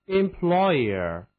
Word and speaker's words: employer: 到了雇主，那就是字母 ER 组成的这个后缀，读作 a，所以是 employer。